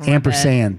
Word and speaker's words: ampersand: Did you say Ampersam? – Did I say it wrong? – Sand. – Yeah. – And I said it wrong. I said Ampersam. – It's my favorite bit of typography. Yeah. So I just wanted to make sure you said it correctly.